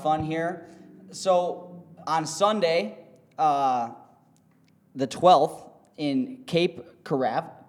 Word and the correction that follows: Cape Canaveral: Cape Corral? – How do you say it? – Cape Canaveral? Hang on. – Cape Canaveral. – I butchered it.